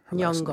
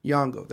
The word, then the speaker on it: Nyong'o: Harvey Weinstein accused by dozens, specifically disputes Lupita, how you pronounce her Nyong'o. – Last name. Nyong'o, there we go.